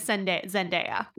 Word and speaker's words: Zendaya: It is Zendaya. – Zendaya. Okay, that's really good to know.